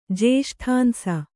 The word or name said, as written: ಜೇಷ್ಠಾಂಸ